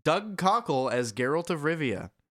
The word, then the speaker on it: Doug Cockle: Doug Cockle.